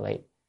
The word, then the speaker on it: vị: Chữ này đọc là vi, vi đọc là vị, còn chữ cố là nên, bởi vì có thể làm chướng ngại đối với sự tu đạo.